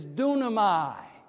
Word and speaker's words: dunamai: The word is dunamai, right? Phonetically spelled D-U-N-A-M-A-I.